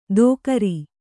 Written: ದೋಕರಿ

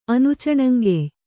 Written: ಅನುಚಣಂಗೆಯ್

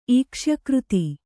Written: ಈಕ್ಷ್ಯಕೃತಿ

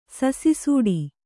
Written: ಸಸಿಸೂಡಿ

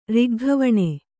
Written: ರಿಗ್ಗವಣೆ

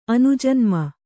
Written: ಅನುಜನ್ಮ

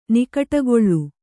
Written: ನಿಕಟಗೊಳ್ಳು

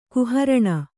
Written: ಕುಹರಣ

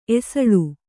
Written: ಎಸಳ್

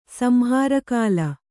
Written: ಸಂಹಾರಕಾಲ

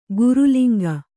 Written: ಗುರುಲಿಂಗ

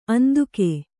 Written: ಅಂದುಗೆ

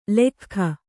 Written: ಲಖ್ಖ

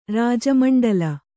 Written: ರಾಜಮಂಡಲ

